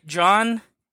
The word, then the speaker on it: Can: Baskin means raid, and it's the first feature by John Evan Nall. That's what's funny about his name. It's spelled C-A-N, but in Turkish, it's pronounced just like John.